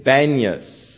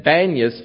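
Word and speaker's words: Banias: But the Arabs had difficulty pronouncing peas, and they said banyas for panyas, and so the name has come to be banyas now, of this area that was once Caesarea Philippi.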